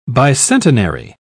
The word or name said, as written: bicentenary